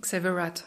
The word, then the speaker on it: Xavier Rudd: Wie hast du den, oder Rudd, oder wie wird er ausgesprochen? – Xavier Rudd, ja.